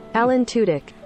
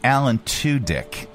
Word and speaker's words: Alan Tudyk: Alan Tudyk. – We were right. – There you go. Yeah, were correct. Yay! It's Alan Tudyk.